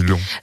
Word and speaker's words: Laon: Oui, l'artichaut grand vert de on dit long, long. – Long, ça s'écrit laon, mais je crois qu'on dit long.